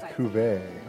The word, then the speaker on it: cuvée: Your question is, the blending wine term cuvée is pronounced cuvée, cove-e, or blend. – I'm going to go with cuvée. – Oh, you're correct.